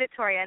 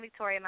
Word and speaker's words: Victoria: So it's, so on Facebook and on Twitter, it's M-A-D-E-L-Y-N-V-I-C-T-O-R-I-A. – Yes, yes, Madeline Victoria. And that is my real name, Madeline Victoria.